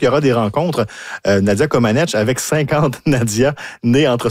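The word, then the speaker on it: Comăneci: On parle d'un spectacle de variété auquel assistera bien sûr Nadia Comaneci. Hein. On a appris cette semaine qu'on ne plus prononcer le I. Mm-hmm. Alors, c'est 49 ans d'erreur. Et là, finalement, on rectifie le tir.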